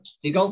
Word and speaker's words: Segel: How does he pronounce it? Is I think it's Siegel? Jason Segel. – I think. Okay like Jonathan Livingston seagull, but spelled different.